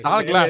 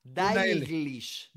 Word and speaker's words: Dalglish: Talglas. Ah, eh, eh, es Irish. creo que tiene una I. Creo que tiene, una, una I. Talglish. No, Daglish. Tiene una L. Dalglish. Dalglish.